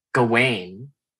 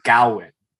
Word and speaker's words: Gawain: So the main two, you can either say Gawain or Gawain. And it's how do they pronounce it in the movie? – They use three pronunciations. It's spelled Gawain, but the three pronunciations are Gawain, Gawain, or Garwin, which is how only one character in the film pronounces it.